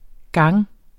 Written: gange

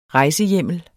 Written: rejsehjemlen

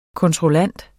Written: kontrollant

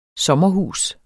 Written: sommerhus

